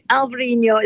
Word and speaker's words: Alvarinho: Alvarinho is how they pronounce it, and Albarinho in Spain. It depends which side of the border.